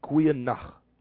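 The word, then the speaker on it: Goeie nag: It's spelled N-A-G, Kwe Nach. It's good night, Kwe Nach. My favorite one, Krute, and it's greetings, G-R-O-E-T-E, Krute.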